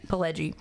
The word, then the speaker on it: Pileggi: And the unrepentant Horace Pinker, played by Mitch, how do you say Pileggi. – His name? Pileggi.